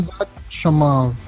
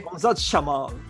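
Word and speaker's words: Hamzat Chimaev: Everyone, my fellow anchors and analysts here in the show will show you now how to properly pronounce his name, okay? One more time. – Hamzat Shamav. – Okay. – Hamzat Shamav.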